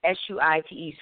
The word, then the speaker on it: Suite: I am Suite, S U I T E. Suite Franchon is my name, spelled like a hotel suite, but it sounds so sweet like candy.